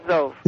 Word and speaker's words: Zov: it's Zove, like in Jove, I guess. – Is it Zove? – Yeah. That's the official pronunciation, right, Zove? – Yes, it is Zove.